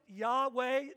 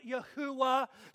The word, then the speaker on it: Yahweh: And we pronounce it Yahweh today. We may pronounce it Yahuwah. Sometimes it's pronounced Jehovah, Jehovah. Different variations. But it means Yahweh is compassionate.